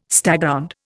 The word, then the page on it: staghound: Indian